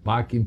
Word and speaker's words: pacem: E pacem se escreve passem, com C, mas é que tinha provavelmente som de K.